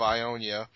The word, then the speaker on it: Ionia: We just got contacted by Omer of Ionia.